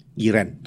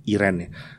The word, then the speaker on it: Eirene: Dan heran, di dalam bahasa Yunani ada kata namanya Iren. Iren, tulisannya Eirene, Irene. Orang Inggris bilangnya Irene. Sebenarnya bacanya Iren.